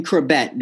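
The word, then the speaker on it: Chrebet: Trebet. Did I say that right? – Wayne Krebet. That's very, very recent, Joe.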